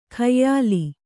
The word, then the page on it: ಖಯ್ಯಾಲಿ: khayyāli